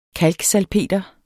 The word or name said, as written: kalksalpeter